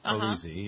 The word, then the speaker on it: Polizzi: Is that how you pronounce her last Polizzi. – Name? Polizzi, yeah.